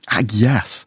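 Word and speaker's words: Agnes: Here is Jesse Buckley, who plays, as they say back in those days, back in interchangeable language, back in Tudor times, they pronounce it Agyes, or Anne Hathaway, as we know, and also Paul Mescal, who plays William Shakespeare, both nominated for Golden Globes.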